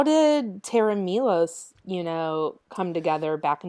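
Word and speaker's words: Tera Melos: So, well, the way you pronounce it is Terra Milos. Terra Milos.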